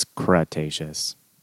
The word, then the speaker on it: Cretaceous: It's Cretaceous.